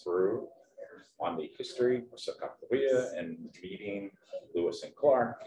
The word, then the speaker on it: Sacagawea: The little robot is, in this case, was dressed up like Sacagawea, which here in Minnesota, everybody says Sacagawea, but right, the proper local pronunciation is Sacagawea.